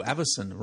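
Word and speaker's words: Avacyn: Behind us, we've got a big, you know, the Shadows Over Innistrad magic banner, and it's got the angel Avison. Ooh, Avison rhymes with Alison.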